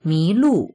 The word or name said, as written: mi2lu4